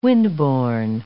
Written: wind-borne

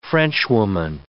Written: Frenchwoman

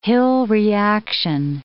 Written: Hill reaction

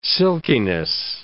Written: silkiness

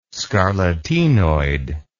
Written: scarlatinoid

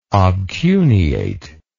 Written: obcuneate

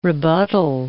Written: rebuttal

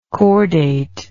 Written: cordate

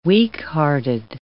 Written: weakhearted

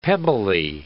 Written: pebbly

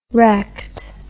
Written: rect

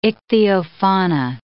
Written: ichthyofauna

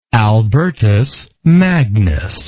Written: albertus magnus

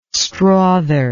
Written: strother

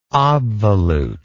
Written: obvolute

